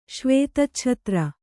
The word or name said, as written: ಶ್ವೇತಚ್ಛತ್ರ